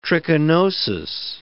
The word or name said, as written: trichinosis